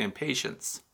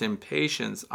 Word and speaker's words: Impatiens: Now, I know a lot of people mispronounce that and say impatience. So it's impatience, I-M-P-A-T-I-E-N-S.